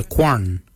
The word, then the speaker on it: gwan: Kwalak is spelled G-W-E-L-Y-A-G. Kwalak. Kwalak is your hips. Your backside is your quan. Your quan is spelled G-W-A-N.